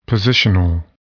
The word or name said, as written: positional